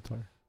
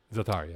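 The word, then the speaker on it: Za'atar: I believe it's pronounced Zatar. – Zatar, yeah.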